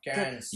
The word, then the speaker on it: Cairns: Have only ever been to Cairns, is that how you pronounce it? – We are about to move to Cairns. What? – Cairns.